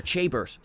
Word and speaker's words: Chabert: So is it Lacey Chathbert or Chathbert? Or Chabert. Sorry, there we go. I added a TH where there wasn't one. – I don't know if it's Chabert or if it's Chabert.